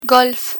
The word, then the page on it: golf: Spanish